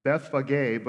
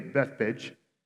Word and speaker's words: Bethphage: Now when they drew near to Jerusalem and came to, it's actually pronounced Bethphage, but we'll say Bethphage,